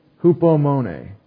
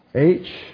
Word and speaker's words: hupomone: Long E. That's an European or Greek E, A. It says A. All the other languages that are kind of like English kind of say the long A sound in English for the long E. Hupomone. What does that word mean? Rough breathing. That's the Greek H right there.